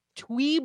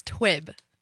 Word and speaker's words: TUIB: Tweeb acapella? Am I saying that right? What? – So close. It's twib. – Twib. – Yep.